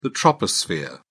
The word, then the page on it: troposphere: British English